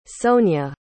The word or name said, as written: Sonya